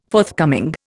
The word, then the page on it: forthcoming: Indian